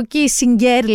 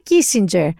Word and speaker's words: Kissinger: Το όνομά του γερμανικά προφέρεται Κίσιγκερ. Οι Αμερικανοί όμως που στην Αμερική ήταν υπουργό εξωτερικών και έζησε όλη του τη ζωή, όπως και οι Βρετανοί τον λένε Kissinger.